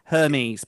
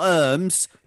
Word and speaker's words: Hermes: Hermes, Petal. – Hermes, yeah. – Yeah, not Hermes, like germs in all. – Hermes. – None of that. Hermes.